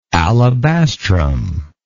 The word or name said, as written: alabastrum